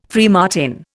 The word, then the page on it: freemartin: Indian